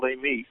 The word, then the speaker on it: Amite: Am I getting that name wrong? – Actually, you are. It's called Amite.